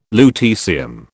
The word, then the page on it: lutecium: British English